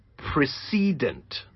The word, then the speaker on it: precedent: The word precedent can also be an adjective which means preceding or coming before. In this context, however, the pronunciation becomes precedent.